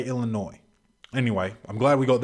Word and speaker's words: Illinois: And I just said Illinois, and I hate that because it's Illinois, and you don't pronounce the S.